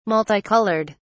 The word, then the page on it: multicoloured: British English